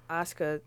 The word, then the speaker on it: Asuka: Yeah, he had tweeted like, oh, I guess they're scared that Asuka, did I say it right this time? – Yeah.